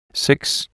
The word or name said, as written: Six